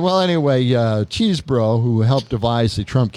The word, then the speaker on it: Chesebro: Yes, just like cheese and bro. Not Chesebro, Chesebro. No, Chesebro.